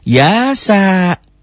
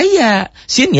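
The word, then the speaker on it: ya, sin (يس): Kedua, membacanya jangan dibaca ya-sa. Gitu. Bacanya bagaimana? Seperti kita mengeja huruf hijaiyah. Ya, ya, ya. Sin, ya, sin gitu loh. Ya. Makanya ini pentingnya pelajaran tahsin yang Sabtu malam Minggu gitu. Jadi jangan ya, sa, jangan. Bacanya gimana? Ya dan sin gitu.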